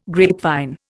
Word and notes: grapevine: Indian

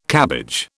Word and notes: cabbage: British English

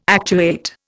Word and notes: actuate: Indian

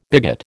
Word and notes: bigot: American English

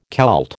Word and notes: Kelt: American English

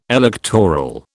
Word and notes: electoral: British English

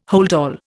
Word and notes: holdall: Indian

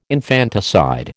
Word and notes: infanticide: American English

